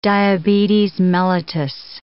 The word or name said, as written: diabetes mellitus